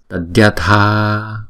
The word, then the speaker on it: tadyatha: Còn chữ diya này nè, chúng ta đọc như là tadya, tadya, tadyatha, phải không?